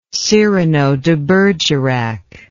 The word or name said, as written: Cyrano de Bergerac